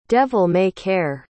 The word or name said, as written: devil-may-care